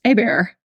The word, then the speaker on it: Hébert: And my last name is spelled H-E-B-E-R-T, which people will see if they're listening to this. But it looks like Hébert. It looks like Hébert, but it's pronounced Hébert because it's French.